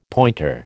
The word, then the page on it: pointer: American English